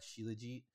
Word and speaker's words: Shilajit: I think most of them landed on Shilajit. That's what I'm going with.